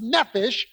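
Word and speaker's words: nephesh (נֶפֶשׁ): The Hebrew word for soul is pronounced nephesh, and nephesh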